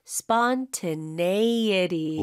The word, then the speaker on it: spontaneity: So, N-E 쪽에다 강세를 좀 많이 주시면 좋겠습니다. – 네, 그리고 네. 여러분 강세는 연습만 계속 하시면 돼요. 사실 어렵지는 않고 계속 연습하면 돼요. – Spontaneity.